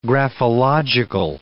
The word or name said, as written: graphological